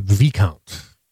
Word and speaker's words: Viscount: I think they pronounce that Viscount.